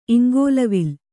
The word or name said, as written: ಇಂಗೋಲ್ವಿಲ್ಲ